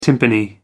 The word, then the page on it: timpani: American English